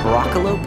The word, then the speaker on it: Broccolope: I go by Broccolope, that's spelled BROCCOLOPE, and Nate goes by Nate_McKeever.